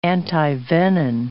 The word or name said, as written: antivenin